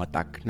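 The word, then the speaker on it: ATT&CK: Y se escribe ATT CK. Suena algo así como ATTACK, ¿no? Como si lo ves en inglés.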